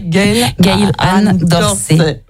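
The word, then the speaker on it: Gail Ann Dorsey: Gail-Anne Dorsey. – Voilà, prononcez-le, Gail-Anne Dorsey.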